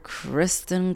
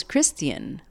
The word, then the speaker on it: Criston: I thought his name was Christian, like Christian Cole. No, it's just Criston Cole. So sorry, Criston. Anyway, just wanted to get that correction out there. His name is Criston, not Christian, which I prefer to say Christian, but his name is Criston, so we shall call him that.